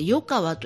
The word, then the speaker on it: 横川: それから横川と書いてこれで横川と読むんですが、横川地区その 3 つの地区があって、それの全体で比叡山延暦寺と。